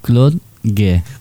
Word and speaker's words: Claude Gay: Ahí sí, ahí se sale, no, mucho mejor. Se sale mucho mejor a ti. ¿Cómo es? – Liceo Claude Gay.